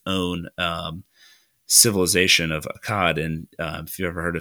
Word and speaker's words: Akkad: And then in the north, you had a city of Akkad or Akkad. I don't know how you want to pronounce it. If you want to put a little southern twang and say Akkad. – Yeah, I think it's Akkad, actually. – Yeah, it is.